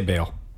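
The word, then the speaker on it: Baal: Yeah, the Ball system with the planet Ball. Bale? How do you say it? – I would say Bale.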